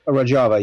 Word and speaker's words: Rojava: Is that, am I pronouncing that right? – Rojava, yeah.